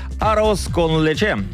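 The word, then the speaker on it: arroz con leche: А также арос кон лече. Именно так это произносится.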